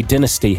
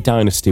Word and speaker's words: dynasty: They say it depends where you are. We say dynasty here, although I say dynasty, which is the American pronunciation for that.